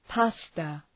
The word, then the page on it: pasta: ‘pæstə